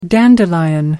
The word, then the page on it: dandelion: American English